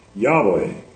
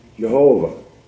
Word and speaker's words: Yahweh: I am in Hebrew is probably pronounced Yahweh, or more popularly but less likely, Jehovah.